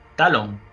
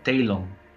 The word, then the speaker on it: Talon: Bueno, pues una banda que se llama Silver Talon, creo que lo he pronunciado bien, que la segunda palabra se escribe Talon. – Ah, Talon.